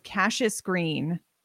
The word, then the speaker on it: Cassius Green: So like the main character is Cassius Green, pronounced like Cash is Green.